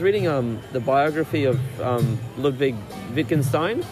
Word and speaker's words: Wittgenstein: It's spelled with a W, but it's German, so it's pronounced as a V. Wittgenstein.